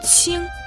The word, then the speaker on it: 青: Слово чин произносится первым тоном, означает синий-зеленый.